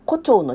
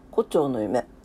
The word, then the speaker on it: 胡蝶の夢: 胡蝶の夢。合ってるイントネーション。胡蝶の夢。胡、蝶じゃないよね。胡蝶の夢だよね。